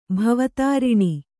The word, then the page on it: ಭವೋತ್ತಾರಣ: bhavōttāraṇa